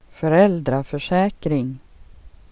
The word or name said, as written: försäkring